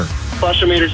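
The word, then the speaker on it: flushometer: flush-o-meter. – Wait, is that how you pronounce it? – A flush-o-meter. – Flush-o-meter sounds right.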